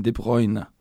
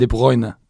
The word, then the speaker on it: De Bruyne: og han sier selv Kevin De Bruyne. En gang til. Kevin De Bruyne. Kevin De Bruyne.